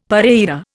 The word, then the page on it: pareira: Indian